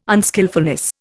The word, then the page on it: unskilfulness: Indian